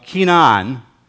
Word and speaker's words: Kenan: Now, we have a definition up there of Kenan. That's how you would pronounce the name of the family, the Kenan family.